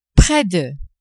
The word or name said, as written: près de